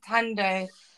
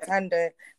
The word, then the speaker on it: Tandoh: Yes, my name's Tandoh. That's my maiden name, my father's name, yeah. But you pronounce it right, it's Deborah Tandoh.